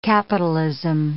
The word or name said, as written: capitalism